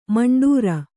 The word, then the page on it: ಮಂಡೂರ: maṇḍūra